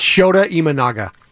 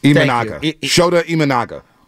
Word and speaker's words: Shota Imanaga: It's Shota Imanaga. – Shota Imanaga.